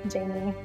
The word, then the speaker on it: Jamie: It's just at Jamie Pacton. So it's spelled J-A-M-I-E.